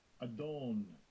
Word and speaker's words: Adon: Adon.